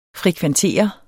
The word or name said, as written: frekventere